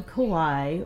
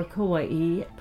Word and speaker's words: Kauai: We're on Kauai, or Kauai, pronounced both ways.